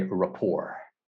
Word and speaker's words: rapport: So this one, just a pronunciation, sorry to interrupt you, Jasmine, but this one actually comes from a French word, which is rapport, but we, in English, we say rapport. So the T is silent because in French, the last letter is typically silent. So just rapport.